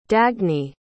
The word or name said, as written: dagny